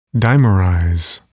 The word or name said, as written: dimerize